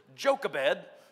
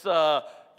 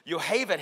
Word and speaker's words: Yocheved: Now, I would just pronounce this Jochebed, but that would be wrong. It's Yocheved. Yocheved,